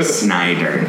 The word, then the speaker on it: Schneider: With our friend Corey Schneider, who was on earlier. – Yeah, different. That's Corey Snyder. Different pronunciation.